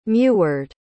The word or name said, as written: meward